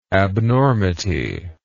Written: abnormity

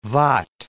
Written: Waadt

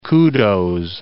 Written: kudos

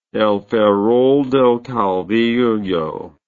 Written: El Ferrol del Caudillo

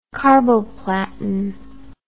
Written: carboplatin

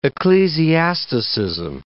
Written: ecclesiasticism

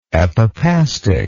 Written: epipastic

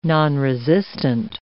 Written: nonresistant

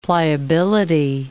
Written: pliability

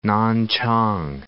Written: Nanchang

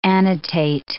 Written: annotate

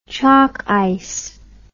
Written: chocice

